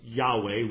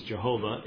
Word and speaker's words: Yahweh: which is probably best pronounced Yahweh. We massacre it in English as Jehovah in our hymns, I think partly because it rhymes better than with anything else.